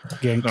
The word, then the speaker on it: Genk: Moved to, is it Ghent or Ghent? I actually should have looked up pronunciations before we started. – Gentlemen. – Genk. No, it's the Belgian team, Genk, isn't it? – Yeah. – G-A-N-K? Yeah. No, I'm wrong. There's a K in it, but it's K-A-A Ghent.